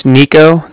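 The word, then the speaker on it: Niko: My name's Nico. That's N-I-K-O. Calling from Bank of Cardiff. That's spelled C-A-R-D-I-F-F, as in Frank, Frank.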